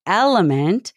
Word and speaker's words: LMNT: Element, spelled L-M-N-T, but pronounced Element, is an incredible electrolyte drink mix that comes in cute little packets that you can bring with you everywhere.